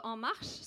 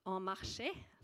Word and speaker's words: En Marche: Både En Marche, som, eh, som da ikke uttales En Marché, som jeg hørte tidligere i dag.